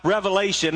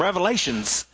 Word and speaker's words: Revelation: Now, when people hear about Revelation, and by the way, that is the correct pronunciation. There's not an S on the end. I grew up in the South, so everybody said, I want to hear a message from Revelations. No, it's just the revelation.